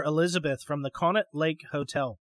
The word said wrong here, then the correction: Conneaut: The Hotel Conet, called, and maybe I'm pronouncing that wrong, Nate. – Conneaut, yeah.